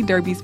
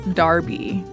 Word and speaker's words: Derby: Derby's First 50 Years. Also, I would like to note that Derby is pronounced Derby in the United States, but in Britain, it's pronounced Darby.